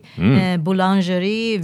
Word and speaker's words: boulangerie: But it also has this amazing French bakery, Boulangerie Villette. And they have fantastic bread. God, is she showing me up here. Of course the immigration attorney is a... Perfect, perfect pronunciation. Say it again, boulangerie.